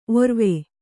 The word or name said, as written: ಔರ್ವ